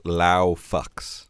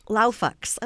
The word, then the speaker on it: LAOFX: I believe it's pronounced LAOFX. – LAOFX. I like that.